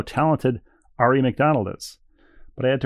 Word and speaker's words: Aari: Ari McDonald, of course, A-A-R-I. – Yes. – So, and the WNBA draft her senior year, she had a sign behind her that said, like, spelled out phonetically, Ari.